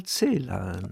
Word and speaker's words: Celan: Leute gibt es, die sprechen in Celan oder Celan aus. Ich sage immer, er hat sich immer gemeldet am Telefon mit hier ist Paul Celan. – Also die Betonung auf, auf der, ersten, der ersten Silbe.